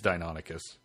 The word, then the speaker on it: Deinonychus: Ask him how to pronounce Deinonychus the Because it, I've heard it Deinonychus, Deinonychus, Deinonychus. I've always heard Deinonychus, and if it follows the rules of Greek pronunciation, like a shitload of um, uh, English words do, weirdly, it's Deinonychus.